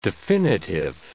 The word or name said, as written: definitive